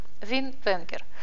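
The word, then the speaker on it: Win Wenger: Вин Венгер. Через W пишется имя. Как слышится, так и пишется.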